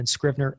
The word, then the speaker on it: Scrivner: So that's Daniel Daniel, and then Scrivner, S-C-R-I-V as in Victor, and as in Nancy, E-R.